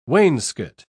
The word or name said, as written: wainscot